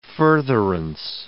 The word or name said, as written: furtherance